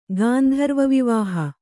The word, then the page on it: ಗಾಂಧರ್ವವಿವಾಹ: gāndharva vivāha